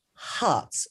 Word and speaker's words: Harz: Now, I called it South Harz because it is actually spelled H-A-R-Z, but Dr. Chris actually said to me it's pronounced Harts, so South Harts Potash.